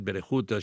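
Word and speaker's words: Verwoerd: En Berehut, el governador Verboet, Berehut, així ho pronunciaven.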